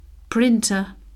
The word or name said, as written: printer